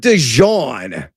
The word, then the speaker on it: DeJuan: And are you, is it DeJuan? Because I've like pronounced his first name like 15 different times. DeJuan Stribling is what we're going with. Is that right? – I go with DeJuan. – DeJuan. Stribling. That's probably right.